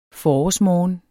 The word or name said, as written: forårsmorgen